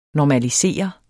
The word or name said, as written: NORMALISEREDES